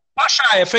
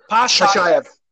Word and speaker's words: Pashaev: Pashaev, exactly like you said it. Pashaev.